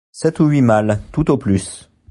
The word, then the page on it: malles: french